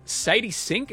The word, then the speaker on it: Sadie Sink: And Sadie Sink, is that how you say her name? – Yeah.